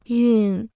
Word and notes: 熨: yù

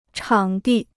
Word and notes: 场地: chǎng dì

